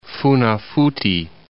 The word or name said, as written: Funafuti